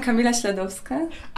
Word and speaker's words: Kamila Śladowska: I'm Kamila Śladowska.